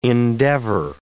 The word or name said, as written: endeavor